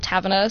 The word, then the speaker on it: Tavener: It's Clara underscore Taverner, so it's C-L-A-I-R-A underscore T-A-V-E-N-E-R.